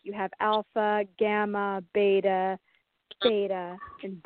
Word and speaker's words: theta: And you are a theta healer. Am I correct? – Yes. – Okay. – And for anyone who's wondering what that is, it's spelled T-H-E-T-A. – Yes, theta.